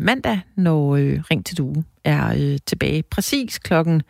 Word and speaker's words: Due: Nu fik jeg jo ikke udtalt det rigtigt. Det er DUE, altså ja. D-U-E.